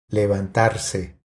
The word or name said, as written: Levantarse